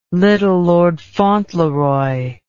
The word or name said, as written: Little Lord Fauntleroy